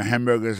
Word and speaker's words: hamburger: N'a pas remarqué que dans le mot hamburger, ça comme tu as dit, il y a trois syllabes là-dedans. Quand tu appuies et puis plus ça, c'est sous premier syllabe là.